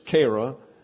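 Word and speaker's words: chera: It's Kara, and that's C-H-E. The long E is pronounced as a long A in English.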